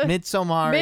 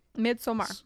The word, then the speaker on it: Midsommar: Midsommar is how you say it in Sweden. – Midsommar.